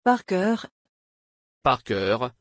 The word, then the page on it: parker: French